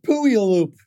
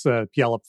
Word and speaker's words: Puyallup: So I think it's pronounced Puyallup. Pretty sure about that, Sean.